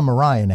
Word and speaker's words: Maria: And this like hot Maria Reynolds, what we call her Mariah now because the way they pronounce it with Lin-Manuel Miranda. But